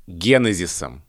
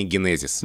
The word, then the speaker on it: генезис: Но особенно, например, если мы возьмем творительный падеж, генезисом. Смотри, после ударного слога три безударных. Это жутко неудобно для языка. И поэтому, как бы мы этому ни противились, А язык толкает нас в сторону ударения генезис.